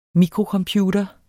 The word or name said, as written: Microcomputer